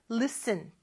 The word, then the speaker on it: listen: Listen이 아니고요. 여긴 발음은 listen. T 발음이 발음이 안 나요.